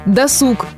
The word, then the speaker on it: досуг: «Досуг» – правильно, все прочие вариации – ошибка. Откуда же такая приверженность некоторых людей к «досугу»? Оказывается, эта ошибка достаточно распространена среди чиновников и военных и считается характерной для бюрократической речи. К слову сказать, некоторые поэты, писатели и журналисты нарочно используют исковерканное ударение, чтобы подчеркнуть неграмотность персонажа или зависимость того или иного человека от бюрократизмов. Ну а мы запоминаем всегда и везде ударение на «у».